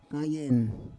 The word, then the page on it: kayen: Roon